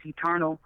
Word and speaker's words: Eturnul: My name is Eternal. It's an acronym, though. It's spelled E-T-U-R-N-U-L.